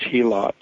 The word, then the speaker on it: helot: And our character is a what we call a helot, or the correct Greek pronunciation is helot.